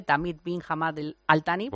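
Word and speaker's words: Tamim bin Hamad Al-Thani: Sí, bueno, porque como vamos a hablar de él a lo mejor en estos días, primero que tiene un nombre impronunciable, Tamid bin Hamad Al-Thani.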